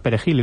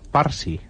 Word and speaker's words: perejil: Los haitianos no sabían pronunciar perejil y lo pronunciaban en criollo o en francés, parsi o pasi, y inmediatamente les asesinaban.